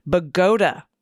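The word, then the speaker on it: Bogota: The town in New Jersey is pronounced Bagoda, like Abe Vigoda.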